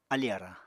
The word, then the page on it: alì arañ: Apatani